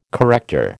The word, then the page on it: corrector: American English